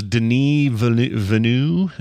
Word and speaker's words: Denis Villeneuve: And I think Villeneuve, or how you say his last, somebody told me yesterday it was Denis Villeneuve, is how you say his name.